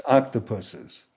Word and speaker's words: octopuses: And for those of you who think I mispronounced the plural of octopus, I didn't. It is octopuses.